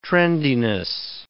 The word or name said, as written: trendiness